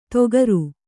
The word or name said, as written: ತೊಗರು